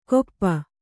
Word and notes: ಕೊಪ್ಪ: kannada

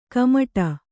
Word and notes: ಕುಮುಟು: kumuṭu

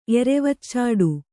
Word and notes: ಎರೆವಚ್ಚಾಡು: kannada

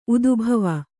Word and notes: ಉದ್ಭಾವ: udbhāva

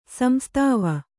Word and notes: ಸಂಸ್ತಾವ: samstāva

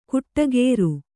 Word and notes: ಕುಟ್ಟಗೇರು: kuṭṭa gēru

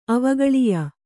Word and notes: ಅವಗಳಿಯ: Kannada

